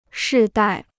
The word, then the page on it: 世代: shì dài